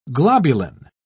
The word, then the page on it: globulin: British English